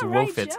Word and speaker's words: woefits: It is woefits. – Right, sometimes it's spelled and said as woofits, W-O-O-F-I-T-S, and it just means a hangover.